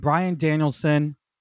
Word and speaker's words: Bryan Danielson: and that's Kenny Omega and Bryan Danielson. I said it right that time, in the ring, confronting one another.